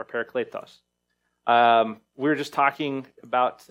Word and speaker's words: paraclete: This is the Greek word, parakletos, paraclete, how we'll often pronounce it in English.